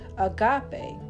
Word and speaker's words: agape: Agape, pronounced agape, is the exercise of one's will for the good of another.